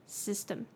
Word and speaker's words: system: 那个 y system 的那个 y 可以看成 i 去发音 system, 好，这就是今天的 pronunciation tips。我们把这句话再重复一遍。Astronomers have found dozens of potentially habitable planets outside of our solar system.